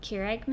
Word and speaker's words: Keurig: Keurig machines.